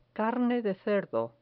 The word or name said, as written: carne de cerdo